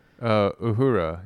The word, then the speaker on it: Uhura: They had a store in Hollywood called Captain Uhura, or how do you say her name? – Uhura, yeah.